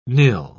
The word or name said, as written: nil